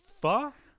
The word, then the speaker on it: pho: I got to have some pho? Is that the correct pronunciation of that? – Yeah.